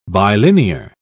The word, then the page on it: bilinear: British English